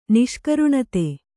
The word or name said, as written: ನಿಷ್ಕರುಣತೆ